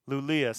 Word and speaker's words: Luleå: Luleå. See, I was saying Luleå, but in Swedish, Älska Luleå.